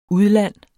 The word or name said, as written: udland